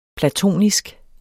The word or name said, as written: platonisk